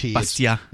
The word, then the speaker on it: Bastiat: His name is pronounced without. – You don't put the T sound in. Bastiat. – Yeah, one of those French things. You just drop off all the letters in the end.